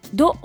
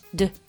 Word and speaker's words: du: その次 Do、これは DU で Do という、英語の Do と同じ発音なんだけどね、あの出始めは。でも Do って伸ばさないでサクッと Do、ちょっと繰り返してみましょうか。